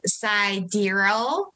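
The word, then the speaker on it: Sideræl: They welcomed a baby girl through surrogate, and her name is Exa Dark Side Rail. And you actually pronounce the middle name as Sidereal.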